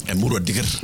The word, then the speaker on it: Emurua Dikir: Emurua Dikir. – Like that. All right. – This is double R. So there's a reason why it's a double R.